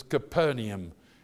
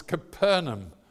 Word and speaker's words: Capernaum: And if you're used to saying Capernaum, you're good to be an Englishman, because all my life I heard it was Capernaum. It's not, it's Capernaum. And if you read it carefully, and Americans do that, you read it properly. But the English don't. They add a syllable for some reason, and I don't know why.